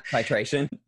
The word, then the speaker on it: titration: Titration.